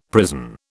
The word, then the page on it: prison: British English